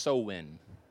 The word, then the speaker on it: Samhain: So Halloween's origins date back to the ancient Celtic festival of Samhain, but it's pronounced Samhain.